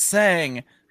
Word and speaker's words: sang: yes. See, it's sang.